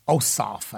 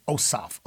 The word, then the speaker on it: Ossoff: This guy, Reverend Warnock, Raphael Warnock, down in Georgia, and this other guy, Ossoff, I think is the pronunciation of his name, Ossoff, O-S-S-O-F-F. Tweedledum and Tweedledee running for U.S.